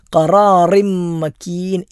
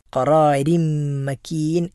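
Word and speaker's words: ഖറാരിം മക്കീൻ (قرار مكين): രീ എന്ന ഉച്ചാരണമാണ് അവിടെ ഉണ്ടാകേണ്ടത്. ഖറാറിം മക്കീൻ എന്നോതിയാൽ ആ പാരായണം തെറ്റായ പാരായണമാണ്. ഖറാരിം മക്കീൻ എന്നാണ് നമ്മൾ ഓതേണ്ടത്.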